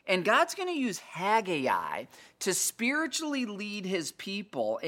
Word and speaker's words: Haggai: Let me spell it for you. H-A-G, Hag, G-A-I, Haggai. That's how I, you know, have always said it, is Haggai. Some people just say Haggai. Say it however you want, okay?